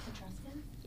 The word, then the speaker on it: Etruscans: Through its archaeological record stretches back tens of thousands of years, Italian history begins with the, I'm probably going to say this wrong, Etruscans? No. – What is it? – Etruscans? – Yes. – The Etruscans. Yeah.